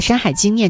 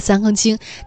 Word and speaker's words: 山海经: 然后另外呢，就是阿长把山海经念成了三更经，这个错误为什么反而会让我们感动？